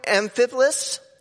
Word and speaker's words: Amphipolis: Now, when they had passed through Amphipolis, I did say that right, and Apollonia.